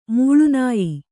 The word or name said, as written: ಮಾಲಿನ್ಯ